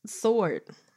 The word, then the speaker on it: sword: Sword. Sword. – Oh, this is what it said? Read it out loud. – Yeah, it says it. For example, in the word sword, you don't pronounce the letter W.